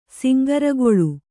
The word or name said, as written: ಸಿಂಗರಗೊಳು